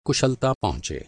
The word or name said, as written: कुशलता